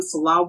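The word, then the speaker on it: Nselaa: Reach me on social media, Instagram, Selah Ward. My name is spelled a little differently. It's N-S-E-L-A-A-W-A-R-D. The N is silent.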